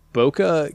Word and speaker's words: Bokeh: Boca Game Studio, at the same time. Boca is, this is spelled B-O-K-E-H.